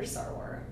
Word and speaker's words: Sarwar: Senator Sarwar.